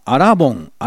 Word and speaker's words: 新盆: 信州では新しいお盆と書いてアラボンですけどね。私が生まれ育った千葉県では同じ字を書いて新盆って呼んでます。